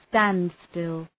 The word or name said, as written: standstill